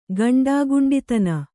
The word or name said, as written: ಗಂಡಾಗುಂಡಿತನ